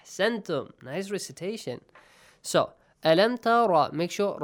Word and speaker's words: تَرَ: أَلَمْ تَرَ إِلَى الَّذِي أَلَمْ تَرَ رَا has, you should pronounce it with a heavy sound.